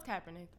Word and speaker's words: Kaepernick: No, it's Kaepernick.